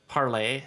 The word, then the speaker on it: Parler: Parlay, spelled Parler, P-A-R-L-E-R. Of course, most people are pronouncing it Parler, so from what I understand, it's Parlay.